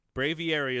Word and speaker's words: Braviary: Braviary is the right way to say it.